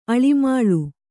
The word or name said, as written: ಆಳಮಾಳ